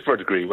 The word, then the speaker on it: Verdigris: Am I getting the pronunciation right? Is it Verdigris? – It is Verdigris. Well done, yes.